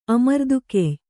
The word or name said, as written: ಅಮರ್ದುಗೆಯ್ಯ